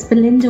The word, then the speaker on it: Belynder: My name is spelled B-E-L-Y-N-D-E-R. And it's Walia, W-A-L-I-A. It's Belinda Walia as Serene Lifestyles.